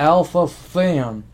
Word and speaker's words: alpha-phenylalanine: Take alpha-phenylalanine. That's how you pronounce it. Alpha-phenylalanine.